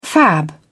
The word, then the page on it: fab: British English